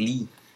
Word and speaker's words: Leigh: Sí, pero se escribe diferente, no se escribe como con doble E como el Bruce Lee, sino que se escribe L-E-I-G-H Lee, así estilo escocés.